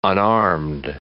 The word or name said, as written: unarmed